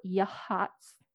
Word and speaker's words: Yachats: So the trip that we went on, we went to Yahats, Oregon, whales, Yahats. It's spelled Y-A-C-H-A-T-S.